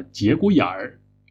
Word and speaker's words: 节骨眼儿: 不过北方人喜欢在后边加上一个儿化音，于是它的发音就变成了节骨眼儿。